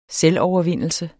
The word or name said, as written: selvovervindelse